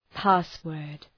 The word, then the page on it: password: ‘pæs,wɜ:rd